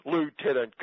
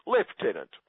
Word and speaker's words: Lieutenant: Lieutenant Colonel, until someone obviously told her the English pronunciation is Lieutenant.